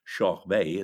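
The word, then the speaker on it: Körberg: Murray Head, Elaine Page and Tommy Korberg, or Schorr Bey as he's pronounced in Sweden,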